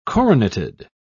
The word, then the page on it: coroneted: British English